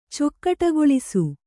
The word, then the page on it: ಚೊಕ್ಕಟಗೊಳಿಸು: kannada